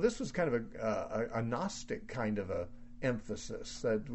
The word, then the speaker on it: Gnostic: The Gnostics, gnostic, or they emphasized gnosko. The word Gnostic, which starts with a silent G, means one who knows.